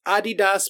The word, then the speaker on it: Adidas: If you're saying it's Adidas because that's how it was pronounced when it was Adi Dassler in the German, blah, blah, blah.